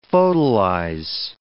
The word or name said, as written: photolyze